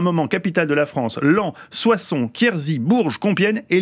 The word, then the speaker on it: Laon: Ah oui, ça s'écrit Laon, mais ça se prononce Lens.